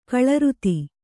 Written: ಗೋಲಾರ್ಧ

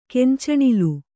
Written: ಕಾಂಚನಾಲ

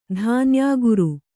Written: ಧಾನ್ಯಾಗುರು

